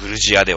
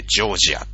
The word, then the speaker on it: ジョージア: 言われてたんだけど、独立したらさ、なんで俺はロシア語読みしなきゃいけねえんだよって、うちもジョージア語ってのがあんだわ、つってね、えー、グルジアではなくてジョージアという読み方に変わりましたね。